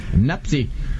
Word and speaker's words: Mpasi: Es más, sí, no va a sonar muy conocido porque la pronunciación es Lionel Mapsi. – Qué capricho, parece un capricho del destino. – Nazú. – ¿Cómo es? ¿Cómo el apellido? – Mapsi. – Es M-P-A-S-I.